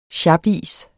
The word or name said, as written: sjapis